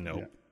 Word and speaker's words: Knope: It's, I believe, called Nope for President, and that's Leslie Nope with a silent K.